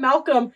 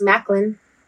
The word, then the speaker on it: Maclean: But in English, it's Macklin. – Well, like Macklemore? – Yeah.